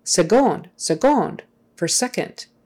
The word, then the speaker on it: seconde: And I'm like, why are they pronouncing that with a G? It's spelled with a C. And then I finally Googled it, and sure enough, that's that's just what you do. You pronounce it with a with a G sound, seconde.